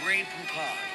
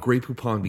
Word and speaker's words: Grey Poupon: From that point on, Grey Poupon became the condiment of choice for rappers to talk about, who wanted an easy word to rhyme with words like futon and crouton that sounded cool but also illustrated the idea of being rich and luxury.